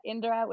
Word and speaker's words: Indra: So you can find me there, or you can find me at Indra, which is INDRUH, but my name is actually spelled with an A, not a UH.